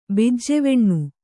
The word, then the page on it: ಬಿಜ್ಜೆವೆಣ್ಣು: bijjeveṇṇu